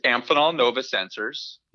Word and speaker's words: Amphenol Nova Sensors: It's Amphenol Nova Sensors.